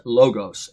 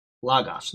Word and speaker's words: logos: Like a lot of people say the word logos, they pronounce it logos. It's not logos, it's logos. Those are omicrons there.